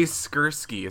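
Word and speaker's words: Skurski: That was from Jay Skursky. Is that how you pronounce it? Jay. – Yeah. – Skursky from the Buffalo News, so we're gonna credit him.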